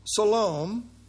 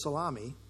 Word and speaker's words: Salome: Their mother elsewhere is called Salome, who I one time early in my ministry mispronounced as Salami.